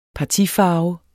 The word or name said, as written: partifarve